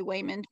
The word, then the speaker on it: Wayman: It's really hard for me to say Wayman because it's spelled just like Raymond with a W.